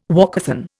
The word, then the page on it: walkathon: Indian